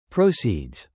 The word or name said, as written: proceeds